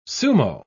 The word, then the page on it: sumo: British English